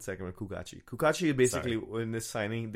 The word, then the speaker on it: Cucatti: Kukachi. It's spelled C-U-C-A-T-T-I, but it's pronounced Kukachi.